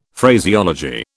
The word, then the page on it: Phraseology: British English